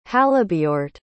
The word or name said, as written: halebeorht